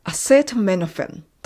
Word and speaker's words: acetaminophen: キャシーはアセトミノフェン。